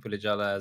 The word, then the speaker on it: Pulijala: So my name is Yashwant Pulajala, as you rightly pronounced it.